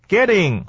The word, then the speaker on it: getting: 再来看到 B 段， B 段的里面的第一段没什么问题，第二段啊 Kim 所说的，对，第一段话里面说 No and I have no way of getting a hold of him、啊、 Getting a hold of him 是、啊、跟他联络的意思。 Getting、啊、 T 念软的，念作了的声音、啊、 Getting 不是 getting、啊、 Getting 这是第一个。